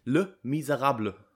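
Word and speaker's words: Les Misérables: Ich habe es mir extra nochmal bei Google rausgesucht und mir angehört, wie man es ausspricht. Le Miserable. Ja, so spricht man es aus.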